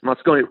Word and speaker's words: Moscone: I think I'm pronouncing his name properly there. – Yeah, Moscone.